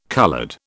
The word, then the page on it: coloured: British English